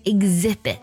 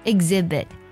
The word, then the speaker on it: exhibit: 还有像 rhythm 跟这个单词也很像啊，它呢同样也是字母 H 在 R 后面，它读作 rhythm 拼作 R-H-Y-T-H-M rhythm。还有下一个呢，在字母组合 E-X 后面 H 也不发音，比如说 exhibit 拼作 E-X-H-I-B-I-T exhibit，那它指的是展览的意思啦。 exhibit H 不发音。